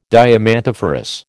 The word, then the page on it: diamantiferous: American English